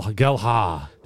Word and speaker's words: Gelhardt: season now, but Harrison, our first Premier League signing, followed by Geldhart. Geldhart. Geldhart. Geldhart. Gel Gelhardt. – Gel Gelhardt. Gelhardt. Gelhardt. Silent D.